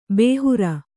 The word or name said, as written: ಬೇಹರ